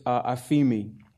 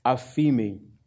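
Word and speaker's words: aphemi (ἀφίημι): So the first one of these words is aphemi. Aphemi. You can see the transliteration there in the parenthesis there. That's how you would pronounce it.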